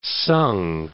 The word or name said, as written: sung